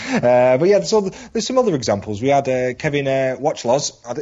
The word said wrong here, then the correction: Wachholz: Is that how you pronounce his name? – I think it's Wachholz. – Wachholz, there we go.